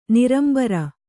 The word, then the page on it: ನೆರಂಬರ್: nerambar